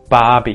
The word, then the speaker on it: Barbie: Barbie. You might notice we're not pronouncing the R in the word Barbie. Barbie.